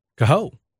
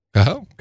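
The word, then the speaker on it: Kahoe: Al Kahu? Kahu? – Mm. – K-A-H-O? Am I saying that right? – I mean, K-A-H-O-O? – No, just one O. – Kahu. – Kahu.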